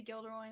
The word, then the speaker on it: Gilderoy: If it was Jill, there would be a J. – Yeah, but sometimes you can, but sometimes words with G are pronounced J. – Except for the fact that they say Gilderoy in the movie, so.